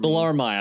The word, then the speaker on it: Bellarmine: I'm probably butchering that. – Is that I thought it was Bellarmine. It might be Bellarmine. – Bellarmine. I think you're right. Yeah. Bellarmine. Yeah. Which is kind of a dumb way to pronounce that, but that's neither here nor there.